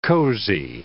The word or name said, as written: cosy